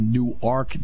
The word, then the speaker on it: Newark: I said, believe it or not, it is Newark, New Jersey, but it's Newark, Delaware. She went, everybody in the class went, what? I said, yeah, it's pronounced Newark.